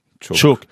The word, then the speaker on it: Chuuk: How do you spell it? – C-H-U-U-K. – Chuuk.